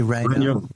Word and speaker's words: Regnault: Henri Regnault.